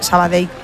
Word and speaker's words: Sabadell: A mí me lo decían mucho cuando estaba en Primera Federación, porque yo también decía Sabadell, que es como se dice.